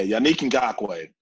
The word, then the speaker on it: Yannick Ngakoue: It's Yannick Ngakoue, right? – Yannick, yes. – Yannick, yeah. Yannick Ngakoue.